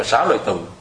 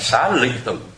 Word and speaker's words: Xá Lị Tử: thì người ta đọc chữ Lị tức là viết giống như chữ Lợi vậy, nhưng mà khi mình đọc thì mình thích đọc về chữ Lợi. Thế cho mình đọc là Xá Lợi Tử, cái thiệt ra đó cái người mà ta phiên âm người ta muốn mình đọc là Xá Lị Tử thì nó mới đúng chữ Xá Lị, đúng cái âm của nó,